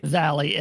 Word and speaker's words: Valli: Also, of course, the title track, you know, Grease, the title track, of course, by Frankie, hopefully I'm pronouncing this right, Valli, is that right? – Valli, it is, yeah.